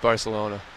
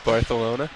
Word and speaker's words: Barcelona: Did anybody pronounce it Barcelona? You know that's the correct way, right? – No, I never heard. – Barcelona, yeah. – Barcelona? – In Spain, they pronounce those as a TH.